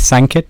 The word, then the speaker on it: Sankit: Hare Krishna, my name is Sankit. I often tell people it rhymes with blanket.